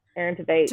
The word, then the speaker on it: Tveit: Or how do you say his last name? – Aaron Tveit.